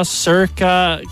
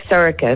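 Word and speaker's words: Saoirse: Well, I prefer it like Erica with an S. So it's pronounced Erica with an S, but it's like Sirica. It's Irish for Sarah. – Sirica. – Yeah, Sirica. – So pronounce it like Erica with an S, but it's Sirica. Like, it's Irish for Sarah, but it means like bright.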